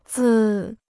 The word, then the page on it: 字: zì